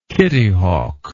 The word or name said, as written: kitty hawk